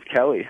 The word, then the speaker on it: Kelly: My name is Kelly.